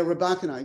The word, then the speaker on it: Rybakina: Yeah, Rybakina, exactly.